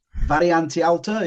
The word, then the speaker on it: Variante Alta: Variante Alta, is that right? Is that how you say it? – That was very good. – That was very good. Yeah, that sounded right.